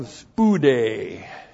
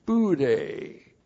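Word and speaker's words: Spude (σπουδή): And then we have spude. Spude, S-P-O-U-D, long E, spude.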